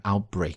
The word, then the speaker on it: outbreak: 好，后边紧跟着就是一个时间状语 ,during the coronavirus outbreak, 就是在新冠疫情爆发期间 ,outbreak 这个单词。那么在读音的时候呢要失去爆破，对吧，要有一个动作做一个宣停。